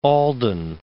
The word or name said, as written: Alden